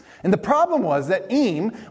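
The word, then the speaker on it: Eyam: And there was this small village just outside of London called Eme, is what I looked it up. It's, they said, how you pronounce it.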